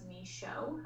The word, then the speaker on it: Michaud: So that's kind of, my last name is Michaud. I know it's not spelled like that, but that's how you pronounce it.